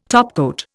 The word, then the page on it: topcoat: Indian